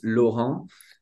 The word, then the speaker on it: Laurent: Going by Frenchie because honestly, my, my first name is unpronounceable in the United States. It's Laurent.